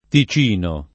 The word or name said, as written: Ticino